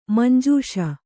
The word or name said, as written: ಮಾಂಜಿಸು